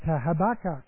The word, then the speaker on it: Habakkuk: You can say Habakkuk a lot of different ways. People say Habakkuk, and Habakkuk is actually apparently how it's supposed to be pronounced.